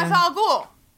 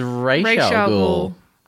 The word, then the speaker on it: Ra's al Ghul: Ra's al Ghul. – Oh, see, you're saying it wrong. It's Ra's al Ghul.